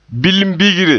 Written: bilimbigre

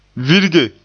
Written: virge

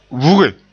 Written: vuge

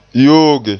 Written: yooge

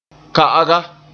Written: ka'aga

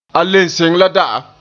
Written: le

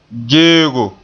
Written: geego

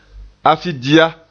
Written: afidia

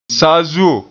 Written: saazuo

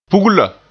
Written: pugla

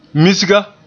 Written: misga

